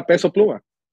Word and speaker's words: Peso Pluma: Yeah, Peso Pluma.